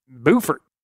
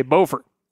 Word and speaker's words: Beaufort: which is actually pronounced Beaufort. Your neighbors in North Carolina say Beaufort.